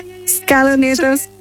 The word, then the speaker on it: Scaloneta: Scaloneta.